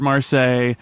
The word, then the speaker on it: Marseille: And our friend Yehuda wrote in to say, oh, the butchering of the word Marseille. Then proceeded to hurt the ears of Francophones the world over by pronouncing the second part like Versailles, which is how you pronounce Versailles.